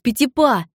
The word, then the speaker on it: Петипа: Хореографа зовут Мариус Петипа! Забавное имя для танцовщика! По-французски оно звучит как «мелкий шажок», хотя пишется чуть-чуть по-другому», — заметил Арчибальд.